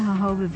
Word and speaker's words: jojoba: Natural Medicine Chest, we'll discuss jojoba, the miracle plant of the desert. – Jojoba is spelled J-O-J-O-B-A, but is pronounced according to its Spanish origins.